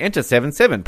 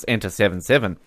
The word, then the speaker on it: Enter 77: I'm glad you read the proper pronunciation, because here is telling me, enter 7-7. Pronounce enter 7-7. – Well, we only talk about facts on the Oz Network. So, Enter 77. You're not a Lost fan if you call it that.